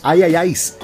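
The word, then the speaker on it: IIIS: Ahora escucharás el corte Nada es Real, que es del proyecto mexicano Ay el nombre se escribe tres I latinas y una S, pero se pronuncia Ayayais.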